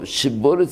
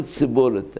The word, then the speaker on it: שיבולת: אבל שם כתוב שיבולת סיבולת, הם מלמדים את הילדים, שין וסין, שיבולת סיבולת. למה? ככה כתוב בשופטים. היו תופסים את בני אפרים, בני אפרים לא יודעים לבטא שין. אומרים, תגיד שיבולת, אומר סיבולת, בני אפרים, היו שוחטים אותו, נורא ואיום.